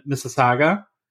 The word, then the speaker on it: Mississauga: in Mississauga, right? Yeah, you crushed it. – There we go. – You crushed that. Yeah, it's in Mississauga, which is a suburb of Toronto.